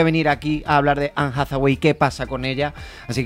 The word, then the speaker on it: Anne Hathaway: Anne Hathaway. – Eva, ¿está bien dicho? – Está perfectamente, Perfecto.